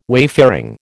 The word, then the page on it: wayfaring: American English